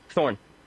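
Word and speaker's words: Thorne: Mr. Thorny, did you know... Thorn. What? Thorn. Like on a beautiful rose stem? The E is silent.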